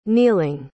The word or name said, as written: kneeling